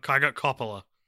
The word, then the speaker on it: Coppola: I got Coppola on my official pronunciation video that I just watched.